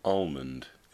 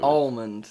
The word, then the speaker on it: almond: So you pronounce the L. – Yeah. Almond. – See, there's a fucking L in it. – I've been told very, very specifically, I think by my sister, never pronounce the L.